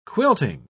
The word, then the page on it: quilting: British English